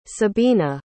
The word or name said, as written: sebina